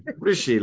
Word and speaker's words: Rishi: Rishi, like Dishy.